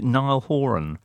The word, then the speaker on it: Niall Horan: Okay, but in the meantime, amuse yourselves with the one that came out today, which features, is it Niall Horan? – Yes.